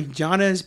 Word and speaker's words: Giannis: Giannis.